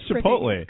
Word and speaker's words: Chipotle: He's like making fun, and that's supposed to be Chipotle. – Chipotle. – Sean Toodle. – Sean Toodle. – Sean Toodle. Sean Toodle. – Which is great. That's Chipotle.